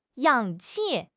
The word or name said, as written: 氧气